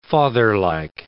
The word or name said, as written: fatherlike